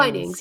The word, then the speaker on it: Twinings: Is it Twinings or Twinings? – Oh, Twinings, yeah.